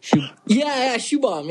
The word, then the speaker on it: Shubham: Shubham.